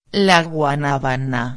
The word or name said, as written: la guanabana